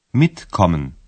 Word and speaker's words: Mitkommen: Ударение падает на первый слог. – Mitkommen.